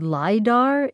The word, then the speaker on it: LIDAR: Something called LIDAR is used.